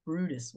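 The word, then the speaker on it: Bryde's: So we decided on the Brutus whale.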